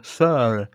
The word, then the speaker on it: Searle: Wie spricht man diesen Nachnamen aus? – Searle. Dabei habe ich meinen Mund ganz komisch verzogen. Searle. Tom Searle.